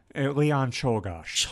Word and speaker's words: Czolgosz: Leon Czolgosz.